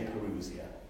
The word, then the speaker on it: parousia: It's called the parousia. Or you can pronounce it in so many different ways, but I say parousia, which means the second coming of Christ in glory at the end of time.